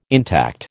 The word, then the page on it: intact: American English